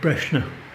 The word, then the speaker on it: Brosna: You'd always know an outsider. They pronounce it like you did there, Brosna. But people from there, they pronounce it Brosna. – Okay. – And the older generation than me even pronounce it differently again. They pronounce it Breshna. – Okay. – So there's three pronunciations of B-R-O-S-N-A.